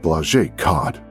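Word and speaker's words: Błażej Kot: The name on the ID was spelled BLAZEJKOT, but they had no clue how to pronounce it. He told the officers that Blaze Kot was the correct pronunciation and then asked if they knew where his wife Caroline was.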